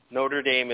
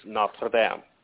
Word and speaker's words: Notre Dame: Notre Dame is not how you say that name. It's Notre Dame.